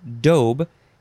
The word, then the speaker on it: daube: The next word is daub, with a long O, daub. It is spelled D-A-U-B-E.